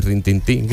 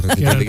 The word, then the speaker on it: retintín: Bueno, o retintín, que no se dice rintintín, que se dice retintín, que lo que venía el todo perro.